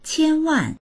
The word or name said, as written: qian1wan4